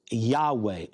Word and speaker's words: Yahweh: Now, Lord, in all capitals, that's translated from the Hebrew word Yahweh or Yahweh. That's how most scholars believe it's pronounced.